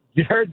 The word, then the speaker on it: Jared: It is absolutely, Jared does not rhyme with ferret. – So, okay. – You guys are putting the emphasis on the wrong syllable.